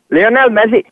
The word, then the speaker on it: Lionel Messi: Lionel Messi.